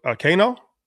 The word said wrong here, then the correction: Kino: Is that how you pronounce his name? – Oh, Kino. Kino. Yeah.